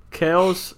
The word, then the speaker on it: Kelce: Well, Kelce, yeah, that's the original pronunciation if anybody cares, but I just... – Anyways. – The same way Americans can't pronounce my last name, they can't pronounce Kelce, so they say Kelce.